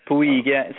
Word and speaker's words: Puig: Puig.